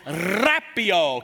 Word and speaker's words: rapio: It says rapio. Can you say that? Roll your R. Rapio. It means seized up, snatched up, raptured away.